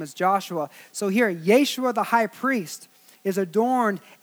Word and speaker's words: Yeshua: Is Jeshua is actually pronounced Yeshua.